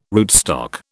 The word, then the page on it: rootstock: British English